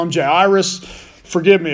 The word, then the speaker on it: Jairus: And there came a man named most of us in the Western world, we call him Jairus. Uh, his actual pronunciation is Jairus, which is neither here nor there.